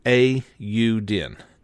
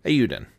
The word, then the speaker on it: Eiyuden: I actually figured out finally how to say Eiyuden. Chronicles 100 Heroes. Let me try that again. Eiyuden. Eiyuden. So that is the official pronunciation of it.